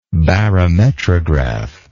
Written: barometrograph

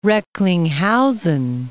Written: Recklinghausen

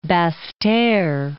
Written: Basse-Terre

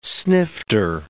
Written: snifter

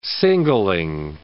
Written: singling

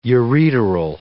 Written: ureteral

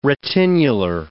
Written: retinular

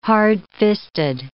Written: hardfisted

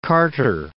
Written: Carter

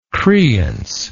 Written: creance

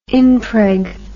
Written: impreg

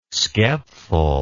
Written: skepful

